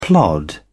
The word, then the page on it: plod: British English